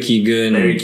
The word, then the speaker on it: Keoghan: Barry Keoghan. – K-E-O-G-H-A-N.